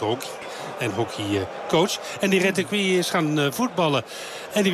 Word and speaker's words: Retegui: Matteo Retegui. En dat moet je op zijn Argentijns uitspreken, want daar is hij geboren.